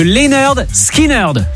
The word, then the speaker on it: Lynyrd Skynyrd: On a encore une heure à passer ensemble en cette journée anniversaire de la sortie du tout premier album de Lynyrd Skynyrd. C'est comme ça que ça se prononce, c'est le titre de l'album.